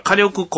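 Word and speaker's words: 花緑: さあ、というわけでですね、久しぶりのボラボラなんですけど、去る 2 月 14日日曜日、バレンタインデーですね、家族 3 人で大阪から名神高速道路を使いましてですね、先ほど申し上げました火力公園というね、聞いたことないね。 – そうですね、うん、花に緑と書いて火力でございまして、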